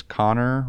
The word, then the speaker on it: Konor: So again, my name is Konor Rockhill, and that's spelled K-O-N-O-R.